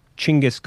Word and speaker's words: Genghis: But it's unfortunately not for Genghis Khan. Or I guess I've seen people say it's supposed to be pronounced Chinggis Khan, but I'm not. I'm saying Genghis.